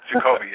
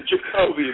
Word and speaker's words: Jacoby: The one that jumped out to me was your call on Jacoby Jones. Did I say that right? – Jacoby, yes. – Jacoby, there we go.